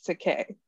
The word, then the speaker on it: Takei: Only thing I know, I went to this conference back in 2019 and I met George Takei, and I got his Takei? – Was it Takei? – Yes, George Takei.